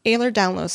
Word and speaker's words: Ehlers-Danlos: Ehlers-Danlos syndrome, which affects my muscles, including my organs.